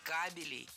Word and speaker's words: кабелей: Дело в том, что кабель имеет неподвижное ударение на основе. И прокладывать нужно кабели. 5 кабелей.